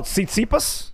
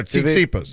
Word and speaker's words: Tsitsipas: Oh, Tsitsipas? – Yeah, Tsitsipas. – The Greek tennis player? Tsitsipas? Yes. – Yeah. – I've heard, not TPP. I've heard Tsitsipas and Tsitsipas.